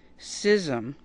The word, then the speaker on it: schism: And I would like to point out that the NPR pronunciation guide is schism. You pronounce it schism.